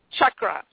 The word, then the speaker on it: chakra: I do know the Sanskrit alphabet, and I do know that it's pronounced chakra.